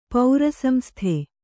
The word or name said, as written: ಪೌರಸಂಸ್ಥೆ